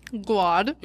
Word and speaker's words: Guad: How do you pronounce that name in Austin? – Guad. It's Guad, right? – But so when it was, it's Guadalupe. – It's Guadalupe Street, but they call it Guad.